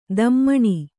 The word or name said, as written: ದಮ್ಮಣಿ